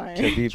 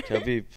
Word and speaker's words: Khabib: Khabib. – Try it. Khabib. – No. – Khabib.